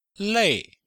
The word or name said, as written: lei4